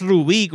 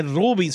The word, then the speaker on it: Ruby: Mira, Mega Pepe nos dice: no vacile que el papá de Zulinka no es Rubí con acento en la I. Bueno, yo sé, pero es que cabrón, yo veo ese nombre, yo pienso en un rubí, no en Ruby. – Exacto. – Es Ruby, suena como.